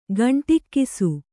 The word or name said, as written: ಗಂಡುಗೂಸು